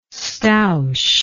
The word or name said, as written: stoush